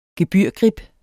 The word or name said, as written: gebyrgrib